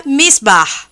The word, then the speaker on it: Miesbach: Se escreve Miesbach, mas se pronuncia Miesbach.